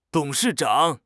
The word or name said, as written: 董事长